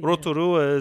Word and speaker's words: Rotorua: Rotorua is just kind of like ingrained in the brain, but Rotorua, you know, Rotorua is the correct pronunciation.